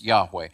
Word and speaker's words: Yahweh: God's name is Yahweh. At least that's the Hebrew designation. We believe it's pronounced that way, right?